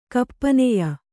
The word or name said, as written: ಕಪ್ಪನೆಯ